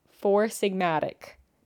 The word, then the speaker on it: Four Sigmatic: So if you want to try it, I'm drinking the adaptogen blend from Four Sigma. Fuck. I didn't pronounce that correctly. I'm drinking the adaptogen blend from Four Sigmatic.